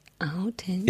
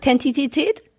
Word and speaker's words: Authentizität: Wie viele Silben hat das Wort Authentizität? – Authentizität. – Ist die richtige Antwort. – Und was habe ich gesagt? Tentizität?